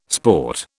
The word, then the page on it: sport: British English